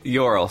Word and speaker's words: Yorl: I want to go talk to that, I think I wrote down his name wrong, Yolorf. – Yorl, so it's Y-O-R-L, Yorl, like Yorl. – Oh, Yorl, like, gotcha. – Yeah, almost like Laurel.